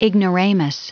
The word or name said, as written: ignoramus